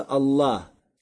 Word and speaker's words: Аллах: Поэтому говорится не «аль-иля», а говорится «Аллах».